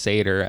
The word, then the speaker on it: Sator: I don't know, it just sounded right, but it's pronounced Sator, as we find out early on in the movie, so obviously that's not a normal word like genetically modified organism.